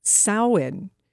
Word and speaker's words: Samhain: Well, Samhain used to be the name of a punk band, but how you say it actually is Samhain. So it looks like Samhain, but it's Samhain, and that is a Celtic festival where Halloween first began, and you can learn about the ancient Halloween mask-making traditions.